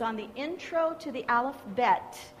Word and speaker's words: Aleph Bet: So yesterday what we learned was an introduction to Hebrew, just the alphabet, and it's called an Aleph Bet in Hebrew. Aleph Bet. If you really wanted to pronounce it correctly.